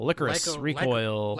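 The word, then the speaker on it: Lycoris Recoil: My number eight is Licorice Recoil. Licorice. Recoil. – Lycorico. – Licorice. Oh, yeah. We found out it's pronounced Licorice Recoil.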